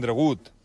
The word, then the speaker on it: Dragut: I doncs, els llatins, per referir-se a Turgut, acabaven pronunciant dragut, clar. Allà es passa que quan passes d'un idioma a l'altre, pues, dragut, dragut, dragut, dragut, que es pronunciaria, a més, amb molt de temor i amb un temor creixent per tota la cristiandat.